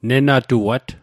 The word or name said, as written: nenatuat